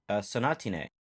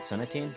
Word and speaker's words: Sonatine: And we're here with our second movie, Sonatine. Which, I guess, corrections department, last episode we pronounced it Sonatine.